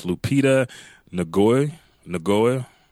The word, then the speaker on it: Lupita Nyong'o: Then you have, and I hate pronouncing her last name, but it's Lupita Nyong'o.